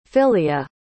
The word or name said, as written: filia